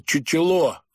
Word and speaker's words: чучело: На их жаргоне такая подмена называется «чучело», с ударением на последний слог.